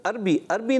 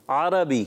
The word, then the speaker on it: عربی: عربی نہیں عربی, کیا کہیں گے؟ – عربی. – عربی زبان کیا کہیں گے؟ – عربی. – عربی زبان. تھوڑا سا اس کا بھی جو ہے پروننسیشن درست کریں, یعنی تلفظ جو ہوتا ہے اس کا درست ہونا چاہیے.